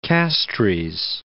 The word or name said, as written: Castries